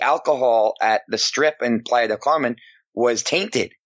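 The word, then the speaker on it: Playa del Carmen: They went to Playa Carmen. Am I saying it right? – Playa de Carmen.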